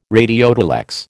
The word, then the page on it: radiotelex: American English